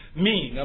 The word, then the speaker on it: mien: And the word main, M-I-E-N, it's pronounced mean. I'm sorry, not main, but mean.